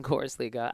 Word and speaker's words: Korisliiga: You did say it right. Nice thing, Korisliiga.